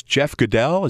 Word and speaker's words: Goodell: it's Jeff Goodell. Is that the right pronunciation? – Yes.